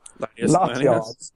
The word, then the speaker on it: Latios: Latios? How do you say their names? – Latios. – Latios.